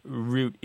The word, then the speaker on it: route: Now, I understand in the U.K., root is the standard pronunciation, and route only generally exists in military circles.